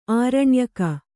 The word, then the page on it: ಆರಣ್ಯಕ: āraṇyaka